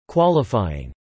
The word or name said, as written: qualifying